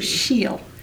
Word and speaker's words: Kushiel: She's going to be reading from her trilogy, which is pronounced how? – the overall trilogy is Kushiel's Legacy. – Kushiel.